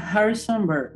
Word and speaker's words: Harrisonburg: Se llama, eh, se llama Harrisonburg.